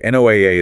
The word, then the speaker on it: NOAA: And remember, NOAA is pronounced NOAA. – Like the ARC.